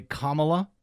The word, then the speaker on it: Kamala: knows how to pronounce the first name of our vice president, which is, by the way, Kamala, not Kamala. But every single speaker has, other than Nikki Haley, has called her Kamala, as if it's not an accident.